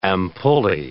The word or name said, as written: ampullae